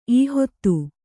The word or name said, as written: ಈಹೊತ್ತು